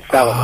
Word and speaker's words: Solomon: It's spelled Solomon. I think it's said Solomon. Is that? I don't know. Maybe I'm. – No, it's radio DJs. John Solomon. – Okay. – John Solomon. – All right. That, so it is. It is said just like it's spelled, as it should be.